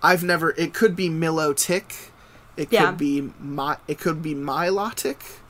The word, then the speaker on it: Milotic: Milotic. – And Bulbasaur. Milotic, thank you.